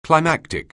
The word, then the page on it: climactic: anglais